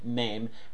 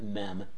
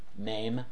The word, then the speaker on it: Mem: It is good that we have a Savior who is just like here in Psalm 119, Mem, and that's how it's pronounced, by the way, not Mem, Mem.